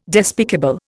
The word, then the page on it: despicable: Indian